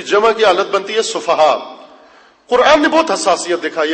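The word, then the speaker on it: سفہاء: دوبارہ ہجے کرتا ہوں, توجہ فرمائیے. سین فے ہے, دو آنکھوں والی ہے, اور الف ساتھ اس کے حمزہ. کیا بنتا ہے تلفظ اس کا؟ سفہا. سب بولیں تاکہ میں مطمئن ہو جاؤں. سفہا.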